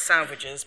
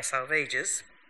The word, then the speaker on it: Salvages: The Dry Salvages, pronunciation may sound unlikely, but Eliot tells us it should rhyme with assuages, so not salvages, but dry salvages, in 1941, and Little Gidding in 1942.